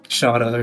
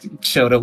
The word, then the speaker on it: Shota: Yeah, so there's like one person who thought like Shota is pronounced Shada or something. Shada. Really, because it just sounds like Shota with an accent. But it's like, take a shot.